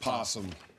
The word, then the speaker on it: opossum: It's spelled opossum. It's pronounced possum.